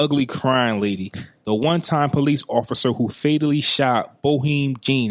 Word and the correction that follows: Botham Jean: Is that how you say his name? Botham Jean.